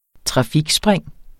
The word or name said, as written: trafikspring